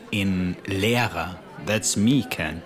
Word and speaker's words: Lehrer: L-E-R-E-R. – Where's the silent H, Ken? – You didn't hear me say it because it was silent. Okay, that wasn't funny. Here's the correct spelling for teacher. L-E-H-R-E-R. – Good. – So nehmen, N-E-H-M-E-N, meaning to take, has the silent H.